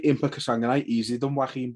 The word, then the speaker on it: Joaquin: I always forget how to pronounce that fella's name. Joaquin Buckley. – Joaquin, there you go. I'll always say that name wrong.